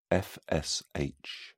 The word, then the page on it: fsh: British English